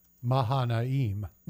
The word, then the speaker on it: Mahanaim: And he named the place Mahanaim.